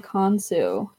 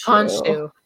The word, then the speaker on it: Khonshu: Right off the bat, I was like, I'm going to have a hard time with this Tok'ra's name because he's pronouncing it Khonsu. But in Moon Knight, it's Khonshu. It's Khonshu.